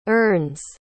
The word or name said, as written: earns